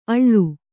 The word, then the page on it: ಅಳ್ಳು: Kannada